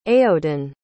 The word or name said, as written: aodan